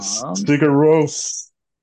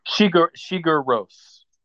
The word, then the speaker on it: Sigur Rós: Sigur Rós. – Oh, Sigur Rós. – I always called him Sugar Ray.